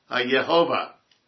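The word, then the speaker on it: Yehovah: It's Hag HaYahweh. I'm sorry, Hag HaYehovah. I used to pronounce Jehovah Yahweh. Then I learned the error of my ways.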